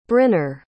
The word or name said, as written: brynner